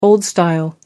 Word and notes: old style: American English

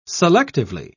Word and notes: selectively: British English

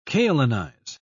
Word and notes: kaolinize: British English

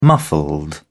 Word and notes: muffled: British English

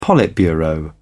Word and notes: politburo: British English

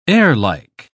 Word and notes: airlike: British English

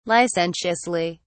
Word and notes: licentiously: British English

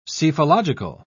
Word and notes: psephological: British English

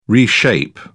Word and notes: reshape: British English

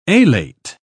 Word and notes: alate: British English

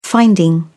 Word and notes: finding: British English

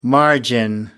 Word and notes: margin: American English

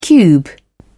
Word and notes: cube: British English

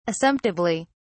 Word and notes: assumptively: British English

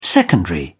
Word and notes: secondary: British English

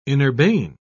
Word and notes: inurbane: British English

